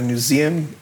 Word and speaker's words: Newseum: And this is Ben Marcus, who is a religious literacy specialist with the Religious Freedom Center of the Newseum Institute. Did I say that right? Newseum? – Yes.